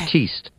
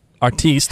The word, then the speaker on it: Artiste: Artiste. – Artiste, that's how you pronounce it.